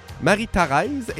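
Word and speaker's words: Marie-Tharèse: Et là, je le prononce un peu croche, me diras-tu? Non, c'est parce que ça s'écrit bel et bien comme ça dans le titre de la pièce. Thérèse, T-H-A-R-E, accent grave S-E. Marie-Thérèse.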